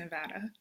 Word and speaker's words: Nevada: You pronounced it correctly. It's always really, it's like, what are they going to say? Are they going to say it correctly? Yeah, I'm from Nevada, and that is how you say it. And that is probably the biggest sticking point for people from Nevada, is that it is pronounced Nevada.